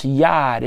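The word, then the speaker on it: Yad (יד): It's a Hebrew word. It's pronounced Yad. It's pronounced Y-A-D.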